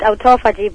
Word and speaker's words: autophagy: The recent study was the restoration of chaperone-mediated autophagy. Did I pronounce that properly? – I normally pronounce it autophagy, but okay. With this accent.